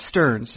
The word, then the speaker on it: Stearns: But last week we discussed in some depth the role of the assistant general manager, because Mr. David Stearns, does that sound right? – Yep, that's how you say it.